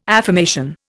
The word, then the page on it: affirmation: Indian